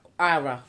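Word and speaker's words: Qawra: It's in Ara, which is spelled with a Q. The Q is silent.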